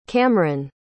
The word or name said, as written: camryn